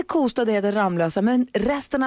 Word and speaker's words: Kosta: Det heter alltså Lovika, det heter Ramlösa och det heter Kosta.